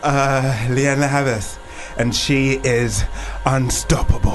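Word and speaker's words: Lianne La Havas: Here's Leanne La Havas.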